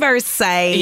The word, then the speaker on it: Versace: It's Versace.